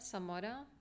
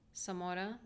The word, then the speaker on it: Zamora: My name is Erlinda Zamora, H-E-R-L-I-N-D-A, Zamora, Z-A-M-O-R-A.